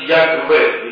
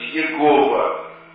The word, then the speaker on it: Яхве: Предположительно, оно произносится как Яхве или Яхве. Но это только предположительно. В древности его читали как Иегова, что неверно.